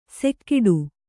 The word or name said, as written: ಸೆಕ್ಕಿಡು